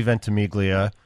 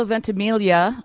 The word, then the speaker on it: Ventimiglia: Wait, I just told Wendy, Milo, that I went to school with a kid named Harry Ventimiglia, but he pronounced the G. I know you don't, but could that be a relative?